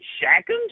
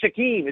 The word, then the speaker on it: Shaquem: His name ain't Shackham. Just read the doggone. Why is it so hard to pronounce? It's like, I can't understand it. Just like his name is Shaquem.